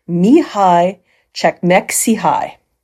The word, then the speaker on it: Mihaly Csikszentmihalyi: It is not at all like it is spelt, but it is pronounced Mihai Csikszentmihalyi.